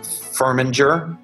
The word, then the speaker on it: Firminger: So this is us signing off for Emma Firminger.